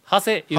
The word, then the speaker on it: 長谷: まあ、そうですね、ということは長い谷と書いて、うん、長谷言うて、